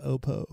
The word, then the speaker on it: Oppo: So 90 gets the Oppo, or come to find out it's pronounced Oppo.